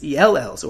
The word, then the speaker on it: ELL: ELLs, or English Language Learners. As you might notice, ELL can be pronounced like the letter L,